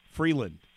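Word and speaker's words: Frelund: You can follow her on Twitter at C Freeland, F-R-E-L-U-N-D. I spelled that right, right? – That was perfect. – I did it. – Said it right, and you spelled it right.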